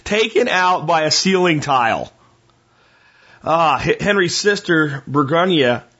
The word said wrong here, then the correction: Berengaria: succeeds to the throne. I'm probably saying that wrong. Berengaria, Queen Berengaria becomes queen as she succeeds to the throne.